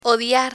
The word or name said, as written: odiar